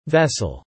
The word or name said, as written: vessel